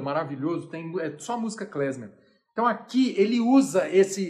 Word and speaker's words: klezmer: Klezmer, que se escreve K-L-E-Z-M-E-R, klezmer, K-L-E-M-Z-E-R, klezmer.